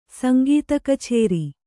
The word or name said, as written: ಸಂಗೀತಕಛೇರಿ